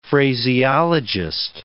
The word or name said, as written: phraseologist